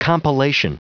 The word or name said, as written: compellation